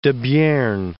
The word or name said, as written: Debierne